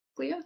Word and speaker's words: Glia: Glia, is how you say it? – Yeah. – Where you're working currently. Yeah. – Correct.